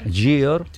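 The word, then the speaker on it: Győr: Decimocuarta de Europa, Campeonato del Mundo, Gyor, yo no sé si lo he pronunciado bien. – Gyor.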